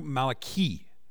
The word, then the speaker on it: Malachi: Malachi's name is pronounced in Hebrew Malachi, and that means my messenger.